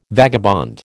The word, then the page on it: vagabond: American English